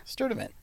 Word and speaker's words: Sturtevant: Sturtevant? – Sturtevant.